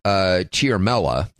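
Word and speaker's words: Ciaramella: It turns out this guy's name is Eric Chiarmella, is the way it's being phonetically pronounced.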